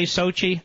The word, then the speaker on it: Sochi: How do you say it? Sochi. You say Sochi, I say Sachi.